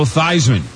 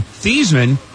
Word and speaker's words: Theismann: future Washington Redskins quarterback and NFL MVP Joe Theismann to change the pronunciation of his name from Theismann to Theismann, that we now know it. So it would rhyme with Heisman.